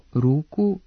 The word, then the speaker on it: ruku: Kako se slog ispred ovog nastavka u genitivu množine po pravilu ne duži, prema Stevanovićevoj gramatici i rečniku srpskoga jezika Matice Srpske, ove oblike trebalo bi izgovarati sa kratkouzlaznim akcentom, dakle, mnogo, ruku, nogu, slugu, uz dozvoljen dubletni oblik sluga sa dugouzlaznim akcentom.